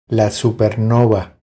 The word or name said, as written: La supernova